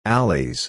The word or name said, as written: alleys